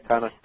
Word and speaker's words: Connacht: And actually is a rather distinguished chef, as he has won the Best Chef of Connacht. Am I pronouncing that right? – That's right, yeah, Connacht.